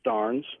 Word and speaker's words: Starnes: Lincoln Starnes, am I pronouncing that correctly? There is no emphasis on the last three letters, is there? – No, sir, just Starnes.